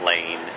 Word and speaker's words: Lane: Don Lane, L-A-N-E.